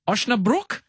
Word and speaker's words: Osnabrück: May 12th, 2024, you have to fix my pronunciation here, Jacko, you're playing VfL Osnabrück? – Yeah. – Have I gone there? – Pretty good, yeah.